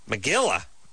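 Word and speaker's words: megillah: Megilla? Who's named Megilla? It rhymes with gorilla.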